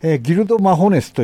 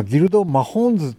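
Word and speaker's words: Gildo Mahones: 6 月 2 日生まれ、 1929年、ギルド・マホネスというアメリカのピアニストでございます。本来英語的にはギルド・マホンズって、発音するのが近いのかもしれませんですが、日本の、ジャズ関係の雑誌にはギルド・マホネスになっております。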